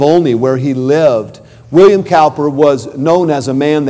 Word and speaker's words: Cowper: William Cowper, sometimes I've heard it pronounced William Cooper, was the man that wrote, "There is a fountain filled with blood."